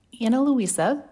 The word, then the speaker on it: Ana Luisa: Ana Luisa, which is spelled A-N-A-L-U-I-S-A, is a jewelry company that offers high-quality pieces at affordable prices starting at just $39.